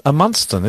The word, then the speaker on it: Amundsen: Ooh, Edmundsen. – How do you say it? – Edmundsen. – No, Amundsen, isn't it? A-M-U-N-D-S-E-N.